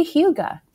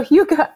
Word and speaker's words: hygge: That would be very hygge. It's a hygge. I didn't know how to pronounce it until I looked it up for you guys. It's that word you sometimes see on Pinterest, H-Y-G-G-E.